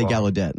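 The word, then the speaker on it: Gallaudet: Is it Gaudet? – That's what I've heard pronounced before, and that's what I say. So we'll both say Gaudet.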